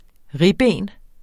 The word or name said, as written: ribben